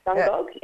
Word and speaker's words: Dungog: Now, I know that uh, this is only the second year that Dungog, have I said it right, correctly? Yep. Dungog, yes.